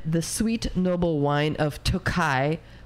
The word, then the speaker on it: Tokaj: So Tohai is actually spelled T-O-K-A-J, but I assumed it's pronounced Tohai. – Tokai. – Yeah. Okay. All right. See? So I was slightly off.